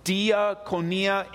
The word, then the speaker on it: diakonia: The Greek word for ministry here is diakonia, or diakonia, in it's spelled D-I-A-K-O-N-I. Different ways to pronounce it, but it's the Greek word.